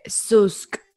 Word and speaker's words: Zoosk: Y uno de esos es uno que se escribe Susk, es Z-O-O-S-K. Bueno, se pronuncia así y se escribe con doble O.